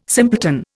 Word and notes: simpleton: Indian

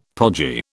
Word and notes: podgy: British English

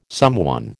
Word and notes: someone: American English

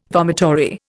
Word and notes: vomitory: Indian

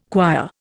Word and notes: choir: Indian

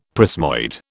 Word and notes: prismoid: British English

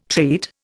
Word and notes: treat: Indian

